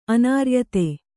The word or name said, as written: ಅನಾರ್ಯತೆ